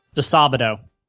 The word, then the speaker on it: Disabato: Disabato.